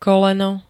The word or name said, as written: koleno